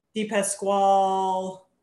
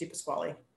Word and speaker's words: DePasquale: DePasquale, they think it's French, and I'm like, no, it's just DePasquale.